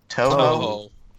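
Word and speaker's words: Touhou: How did you pronounce this again? Toho. – Toho. – Okay. Toho Scarlet Curiosity for PC launches July 11th. Okay. – Yeah, that's it.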